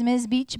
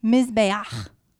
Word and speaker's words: Mizbeach: It looks like it's Ms. Beach, but it's not. It's not a gangster term. It is. You're laughing now. Wait till I pronounce it correctly. It is the altar word, or the Hebrew word for altar is Mizbeach.